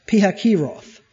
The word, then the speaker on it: Pihahiroth: That's the way it's supposed to be pronounced according to the King James. Pihahiroth.